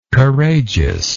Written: courageousness

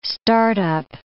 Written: start-up

